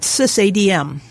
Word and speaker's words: SysADM: One of the major feature additions of TrueOS is a tool called SysADM, and that's how they pronounce it.